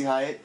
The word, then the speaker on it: Hyatt: Missy Wyatt, why are you there? – I got everything I wanted from Missy Hyatt.